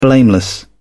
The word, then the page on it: blameless: British English